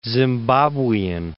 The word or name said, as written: zimbabwean